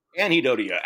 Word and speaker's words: anhedonia: How do you pronounce it? – Anhedonia. – Anhedonia, active anguish and panic fear as forms of suffering.